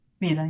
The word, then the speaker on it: Vida: And help me with the pronunciation of your first book. Is it Vida? – Vida, yeah.